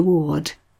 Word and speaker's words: Worde: But let's start by going back further in history again to around 1500, when the wonderfully named Wynkyn de Worde, his surname is spelt like the word word but with an extra E on it, started the whole thing going by setting up a printing shop in Shoe Lane, just off Fleet Street.